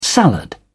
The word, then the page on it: salad: British English